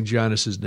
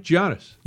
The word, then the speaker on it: Giannis: Andrew said I'm mispronouncing Giannis's name. Am I? – Giannis. – Oh, yeah, you're right. Giannis.